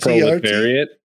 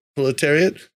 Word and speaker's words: Proletariat: I should have looked out how to say this. Proletiart? – Proletariat. – Proletariat.